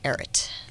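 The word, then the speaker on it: Ehret: It's E-H-R-E-T, Eret.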